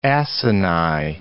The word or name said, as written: acini